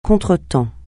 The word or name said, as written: contretemps